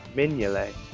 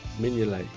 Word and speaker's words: Mignolet: How am I supposed to say Mingale then? – Mignolet. – Mignolet. – Really? – The G is before the N. – Is it? – Yeah. – Mingale. – Mignolet. – G is silent.